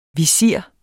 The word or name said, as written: VISIRER